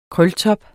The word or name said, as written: krøltop